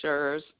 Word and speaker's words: Schurz: Marion Schurz. – Hold on 1 second, please. You say your name was Marion? – Marion, M-A-R-I-A-N. Last name Schurz, S-C-H-U-R-Z.